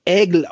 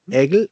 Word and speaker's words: Aigle: Also, es wird Aigle ausgesprochen, Aigle geschrieben, Aigle aus Frankreich.